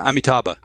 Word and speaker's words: Amitabha: Do you know, how do you pronounce it? – Yeah, Amitabha.